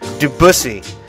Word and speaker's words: Debussy: Debussy.